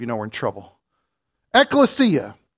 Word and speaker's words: ekklesia: It is the Greek word ekklesia. Let me spell it for you so that you'll understand this. E-K-K-L-E-S-I-A. Ekklesia. E-K-K-L-E-S-I-A.